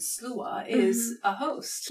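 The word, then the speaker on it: sluagh: So slua is spelled S-L-A-U-G-A. Oh, U-A-G-H. Thank you very much. So slua. – Slua. – Big bad slua. Interesting thing about the word, because we're trying to make a point of saying slua, because that is the Gaelic pronunciation.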